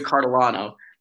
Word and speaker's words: Cartolano: Whoa, David Cartolano. And the last name is spelled Cartolano.